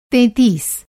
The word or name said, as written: तेंतीस